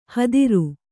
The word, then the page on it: ಹತ್ತಿರು: hattiru